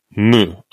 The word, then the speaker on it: Mu: And it's pronounced, how's it pronounced? – I think Mu. – Mu. Okay. So it's different from the Greek letter. – Yes. Yeah, it is in the Greek alphabet too, that same noise.